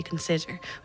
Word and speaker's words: consider: But even, for example, consider, and it's the middle syllable that would be naturally accented when we say consider. We don't say consider.